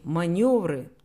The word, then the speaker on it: манёвр: Маневры, только маневры. Маневр, маневренность, маневренный. Тут, собственно, и объяснять нечего. Это слово французское, маневр. В точности так оно и произносится во французском.